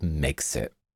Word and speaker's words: Megxit: Like Megxit. It rhymes with Brexit, and it also involves the press making foreigners feel unwelcome in the UK.